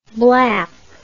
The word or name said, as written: blap